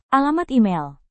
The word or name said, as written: alamat email